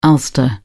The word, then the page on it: Ulster: British English